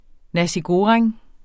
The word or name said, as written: nasi goreng